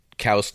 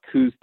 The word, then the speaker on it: Coues: I mean, when you're talking about Coues deer, now I'm going to say Coues, and it's Coues is how you spell it. The actual pronunciation, you are correct.